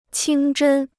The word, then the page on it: 清真: qīng zhēn